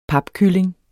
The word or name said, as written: papkylling